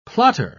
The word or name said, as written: plotter